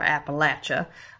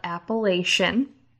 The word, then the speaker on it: Appalachia: We're going to go to, like, Great Depression era Appalachia, which if you're familiar with the, and I'm pronouncing it in the Southern vernacular, Appalachia, some people call it Appalachian.